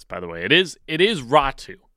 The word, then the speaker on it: Räty: It is Atu Ratu. He has said that's how he wants it pronounced. It's how it's going to be.